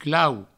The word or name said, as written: clau